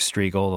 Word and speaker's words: Strigl: So if you don't subscribe to my solo podcast, go to iTunes, search Mark Striegel, the last name is spelled S-T-R-I-G-L, and subscribe.